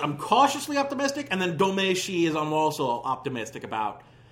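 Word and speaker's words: Domee Shi: Her name is pronounced Domeshi.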